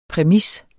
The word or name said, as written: præmis